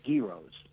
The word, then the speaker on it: gyro: Oh, well, I used to love gyros. – Gyros. – Oh, the gyro. – Thank you for saying it correctly, by the way.